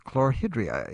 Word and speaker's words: chlorhydria: I took the Heidelberg test on Monday and found out I have severe hypochloridia. Is that how you say that? – Really close. Chlorhydria is the official pronunciation.